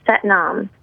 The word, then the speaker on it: Sat Nam: One of my favorite chants is Sat Nam, pronounced just like, but mom.